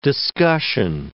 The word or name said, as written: discussion